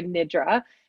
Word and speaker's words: nidra: But so it's traditionally pronounced yoga nidra. And that means, so yoga means union and nidra means sleep.